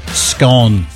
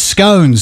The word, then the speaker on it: scone: 55% of people said scone, sounding like gone. 45% said no, it's scone, sounding like cone.